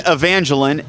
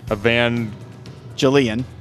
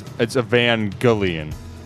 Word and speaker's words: Evangelion: Evangelion. It's Evangelion. – That's right. It's not ja. It's a soft G. Evangelion.